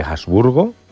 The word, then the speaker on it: Habsburgo: Formó parte de 9 gobiernos en España entre 1900 y 1923, alternando ministerios durante la regencia de María Cristina de Habsburgo, o como se diga en alemán. – Muy bien pronunciado, sí.